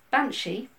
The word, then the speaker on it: banshee: The word's binsai, which is pronounced banshee.